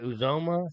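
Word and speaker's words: Uzomah: Did I say that right? Uzoma? – Oh, there you go.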